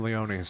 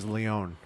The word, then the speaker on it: Leone: I am Leone, so we do the E. But no one ever pronounces the E, you know that? It's always Leone, because I think it's French.